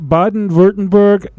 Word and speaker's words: Baden-Württemberg: In Baden-Württemberg, Germany.